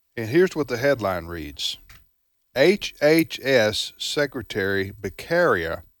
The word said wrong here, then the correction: Becerra: Is that how you pronounce fella's name? Becerra. Becerra. Yes. My apologies.